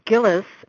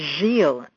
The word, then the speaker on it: Gillis: My surname, Gillis, is my married name, and this originated from Quebec, the French-speaking part of Canada. Whenever I go there, they pronounce it as Gilles, and I don't bother correcting them.